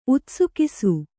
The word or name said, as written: ಉತ್ಸುಕಿಸು